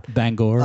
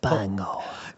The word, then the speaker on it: Bangor: Bangor. – I love that. – Bangor.